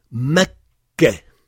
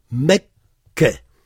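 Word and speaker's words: Mekka (مكة): Mekka. Alors, le kaf est prononcé deux fois, mais écrit une fois. Mekka.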